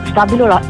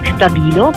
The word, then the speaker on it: STABILO: Stabilo è la pronuncia che esiste solo in Italia. In tutto il mondo Stabilo è conosciuta come Stabilo, perché deriviamo dal tedesco Stabil, ossia la prima mina stabile, fissa.